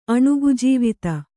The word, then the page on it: ಅಣುಗಜೀವಿತ: kannada